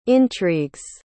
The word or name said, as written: intrigues